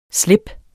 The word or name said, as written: slips